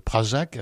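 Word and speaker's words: Pražák: Vous êtes l'altiste de ce merveilleux quatuor qu'est le quatuor Prajak. Alors j'ai peur aussi de mal prononcer. – Non, c'est parfait.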